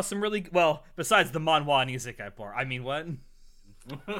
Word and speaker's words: manhwa: Manhwa. – Manhwa. That's an early podcast meme. John used to get on us because we wouldn't pronounce it right. And he's like, actually, it's pronounced manhwa.